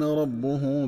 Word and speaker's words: ربه: وتقرأ ربه بضم الباء، فتكون قراءة الكلمتين معا هكذا: وإذ ابتلى إبراهيم ربه بكلمات فأتمهن.